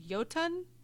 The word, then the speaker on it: Jotun: I mean, they were the it's pronounced Jotun. – My guess is Jotun? No. Jotun. But I mean, because there were like frost giants, of course, fire and mountain giants.